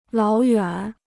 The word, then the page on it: 老远: lǎo yuǎn